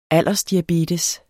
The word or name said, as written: aldersdiabetes